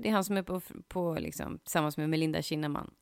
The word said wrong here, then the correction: Kinnaman: Kinnaman. – Kinnaman, oh my god. – Yes, jag fick rätta dig på ett uttal.